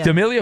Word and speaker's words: D'Amelio: Dixie D'Amelio. Is that, am I saying that right? Yeah, but it's 25. Is that an actress? – D'Amelio.